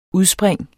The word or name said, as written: UDSPRINGENES